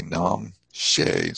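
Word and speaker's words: rnam shes: You see in the Tibetan there, kun, K-U-N, and then G-Z-H-I-I, which is pronounced G. And then the word R-N-A-M, and don't pronounce the R, you say Nam She.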